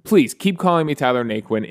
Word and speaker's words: Naquin: Apparently, it has Cajun roots, like Louisiana roots. And down there, they would call it Nacan. That's how they'd pronounce it, Nacan. And when him and his brother were kids, his dad was like, look, everyone's going to pronounce it Naquin. Let's just go with it.